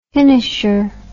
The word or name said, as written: finisher